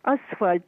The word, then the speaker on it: асфальт: И он знает, что если откажется, то пойдет обратно асфальт. Ну, тут ложить на первом слоге ударение.